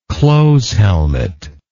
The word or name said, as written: close helmet